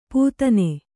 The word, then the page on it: ಪೂತನೆ: pūtane